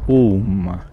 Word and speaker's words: OM: Le mantra des mantras, c'est le son OM, où il y a E, OU et M. E et OU, ça fait O, et M qui est nasal.